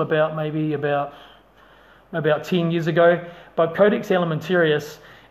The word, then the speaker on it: Codex Alimentarius: Codex Alimentarius. Codex Alimentarius. I'll spell that for you. C-O-D-E-X. Codex, as it reads. And then Alimentarius. Alimentarius. Codex Alimentarius.